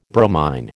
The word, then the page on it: bromine: American English